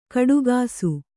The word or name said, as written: ಕೊಡುಗೂಸು